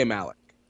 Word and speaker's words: Malek: Is his name Rami Malek? – Rami Malek. – Yeah. – I would say Malek. – I think so, too, because I think it's pronounced like Zayn Malik's name.